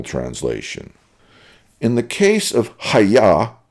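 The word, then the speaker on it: ha-yah (היה): In both cases, the original Hebrew word being translated is, quote, hayah, unquote. This Hebrew word is pronounced with a hard H sound, as in ha-yah.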